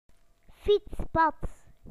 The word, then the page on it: bicycle path: British English